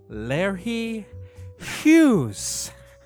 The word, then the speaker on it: Larry Hughes: I'm going to go with my guy, Larry Hughes. – Okay, I wasn't expecting that pronunciation. That is right.